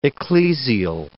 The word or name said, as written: ecclesial